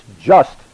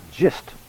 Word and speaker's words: just: It's just, not gist.